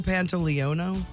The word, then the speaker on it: Pantoliano: One of the scariest tales from this crypt I have ever seen was with Joe Pantaleone. How do you say his name? Joey Pantalones? Pants? Joe Pantoliano. Pantoliano.